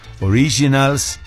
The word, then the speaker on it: Originals: La nueva serie documental de NRM Originals, así se escribe, se pronuncia Originals, que te lleva por esta tierra de tradiciones, creencias, espectáculos naturales, sabores y una identidad que resiste todo.